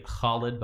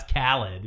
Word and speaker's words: Khalid: We literally call, like, one of our superstars, it should be Khalid, but we say, oh, that's Khalid, you know.